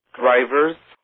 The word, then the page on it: drivers: American English